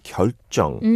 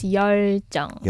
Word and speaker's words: 열정: I heard the way Rao pronounced 열정. To me, it kind of sounded almost like 결정 in a way. – Yeah, yeah, yeah. But it's 열정. – 열정. 결정 means like decision.